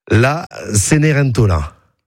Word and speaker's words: La Cenerentola: La Cenerentola.